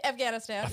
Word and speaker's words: Afghanistan: I mean, people will point to Afghanistan. Is that how you say it properly? – Afghanistan, yeah.